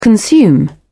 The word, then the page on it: consume: British English